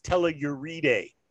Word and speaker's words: Telluride: Jared, it's pronounced tell you ride.